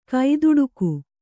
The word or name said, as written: ಕೈದುಡುಕು